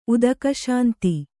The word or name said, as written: ಉದಕಶಾಂತಿ